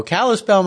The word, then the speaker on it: Kalispell: Kayla Randancer is a fine artist from, is it Kalispell, Montana? Am I saying that right? Yes. There we go. Kalispell, Montana.